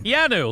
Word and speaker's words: Keanu: How do you pronounce his name? – Keanu, like Keanu Reeves.